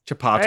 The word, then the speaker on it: Chipotle: Chipotle. Is that how they say it? – Yeah. Chipotle.